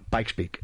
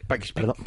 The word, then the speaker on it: Pikes Peak: Pikes Peak. – Pikes Peak.